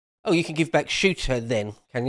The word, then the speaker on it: Ceuta: I had to look up how you pronounce that. Google said it's Ceuta.